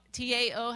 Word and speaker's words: Tao: Tao T A O. How do you pronounce that? Tao.